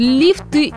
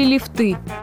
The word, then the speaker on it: лифты: А если он не один, то как будет, лифты или лифты? Сомнения прочь, здесь все по схеме с шарфами и тортами. Ударение неподвижное как в единственном, так и во множественном числах.